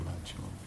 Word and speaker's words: Alachua: With all respect to Alachua. – It rhymes with Atachua.